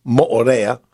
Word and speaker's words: Moorea: You know, as I said, I'm just 2 days back from Tahiti and Moorea, properly pronounced Moorea.